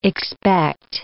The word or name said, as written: expect